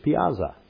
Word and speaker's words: piazza: Italian is piazza. Piazza. Not pizza.